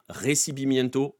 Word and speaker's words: Recibimiento: Je vous invite, si vous ne l'avez jamais vu, à écrire récits Bimiento, comme ça se prononce, R-E-C-I-B-I-M-I-E-N-T-O.